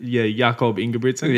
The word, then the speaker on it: Jakob Ingebrigtsen: How do you say this name? Is it, Jakob Ingebrigtsen? – Yes.